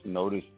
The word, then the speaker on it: Notice: Notice is my real last name. That is not a marketing gimmick. Notice is spelled just like the word, N-O-T-I-C-E.